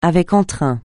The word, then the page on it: avec entrain: francais